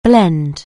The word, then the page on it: blend: anglais